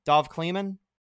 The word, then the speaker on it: Dov Kleiman: There was actually a report from Dov Kleiman, I think is how you say his name. Dov Kleiman? – Yeah. – D-O-V? Dov? His name is Dov? – Yeah, most of the time he just takes reports and like tweets them.